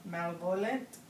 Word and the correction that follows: malevolent: Oh, malevolent.